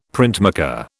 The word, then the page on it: printmaker: British English